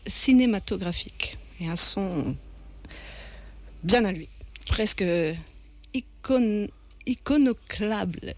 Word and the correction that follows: iconoclaste: C'est iconoclaste.